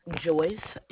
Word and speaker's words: Joyce: My name is Joyce, and how may I assist you? – Did you say your name was Joyce? – Joyce, J-O-Y-C-E.